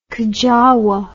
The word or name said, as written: kajawah